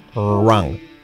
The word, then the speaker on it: wrong: Wrong. Wrong. La W, ahí no tiene ningún efecto.